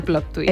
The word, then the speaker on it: plot twist: Bueno, yo quiero luego también hablar del plot twist. ¿Se dice así, querida? – Sí, el plot twist.